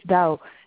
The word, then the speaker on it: Douw: Yes, it's Dao.